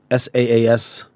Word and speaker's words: SaaS: In conversation with Sadiq Ahmed, who is one of the co-founders of Quicksilver, the largest gift card and prepaid SaaS, is that how you say it? – Yeah.